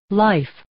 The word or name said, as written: life